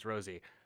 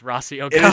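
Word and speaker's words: Rossy Ogawa: Because I told you last week that it was pronounced Rosie. I think I was wrong. I think it is actually pronounced Rossi Ogawa.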